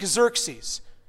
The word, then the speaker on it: Xerxes: So it is in Greek, Xerxes. Who ruled over 127 provinces stretching out from India to Kush. At that time, King Xerxes reigned from the royal throne of the city of Susa, Shushan in the Hebrew, Susa.